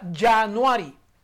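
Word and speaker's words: January: Aunque está comenzando con J, se pronuncia Januari.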